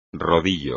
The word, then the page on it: rodillo: espagnol